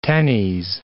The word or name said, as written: tennies